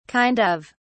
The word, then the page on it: kind of: British English